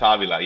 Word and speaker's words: Avila: It's Avila. Yeah, it technically has an accent over the first A.